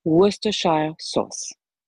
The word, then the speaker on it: Worcestershire: On va quand même corriger cette prononciation. Ça ne va pas du tout. Worcestershire sauce. Je répète.